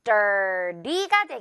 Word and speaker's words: dirty: 우리 미국식 발음으로는 리 발음으로 좀 부드럽게 약화 발음을 해줄 거예요. dirty가 되겠죠. dirty.